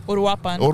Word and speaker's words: Uruapan: Uruapan.